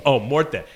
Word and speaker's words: morte: Morte. Yeah. Did I say it right? – You did. I would have corrected you.